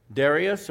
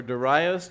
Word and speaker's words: Darius: So the Babylonian Empire has been overtaken now, overthrown, and there's a new king in town, Darius or Darius, depending on how some people pronounce his name, the Mede, under the Persian king Cyrus.